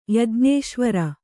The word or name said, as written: ಯಜ್ಞೇಶ್ವರ